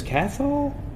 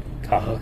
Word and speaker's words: Cathal: No, you don't pronounce the T. – Oh, okay. – It's C-A-T-H-A-L. Or in some parts of Ireland, C-A-T-H-A-L. – That's right. Yeah, the Catholics say H, right? – That's right. That's right. And it's pronounced Cahal. – Cahal. Okay. – So it's a silent T,